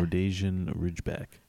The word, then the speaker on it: Rhodesian Ridgeback: Rhodesian Ridgeback.